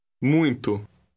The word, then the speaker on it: muito: Muito. Muito. – Note that the letters U-I are pronounced ui.